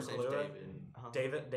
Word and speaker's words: Kaluuya: Yeah, I can't pronounce his last name. – Daniel Kaluuya. Daniel Kaluuya. – Yes.